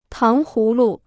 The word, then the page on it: 糖葫芦: táng hú lu